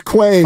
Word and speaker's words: Kwame: Kwame Harris, 26. – Quaym. It's pronounced Quaym.